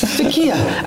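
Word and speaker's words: Türkiye: I need the correct pronunciation. – Takai. – Takai. Takia, actually. No. It used to be Takia.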